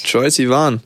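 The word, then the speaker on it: Troye Sivan: Troy Sivan.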